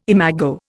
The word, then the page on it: imago: Indian